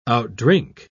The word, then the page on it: outdrink: British English